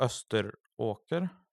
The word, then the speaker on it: Österåker: Och Österåker, är det så det uttalas? – Mm.